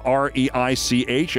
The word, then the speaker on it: Reich: Confronting economist Robert Reich, who spells his name R E I C H and pronounces it Reich,